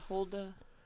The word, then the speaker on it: Huldah: What do Anna, Hudah, Miriam, and Deborah all have in common? Am I saying that right? – Is it Huldah? – I don't know. – Is there an L in it? – Yeah, but it's...